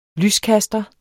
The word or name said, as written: lyskaster